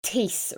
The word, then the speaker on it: Teese: Yeah, so my surname is a bit complicated. It's very anglicized. So Teese, rhyming with peace. – Oh, right. Okay. – So it's not phonetic at all.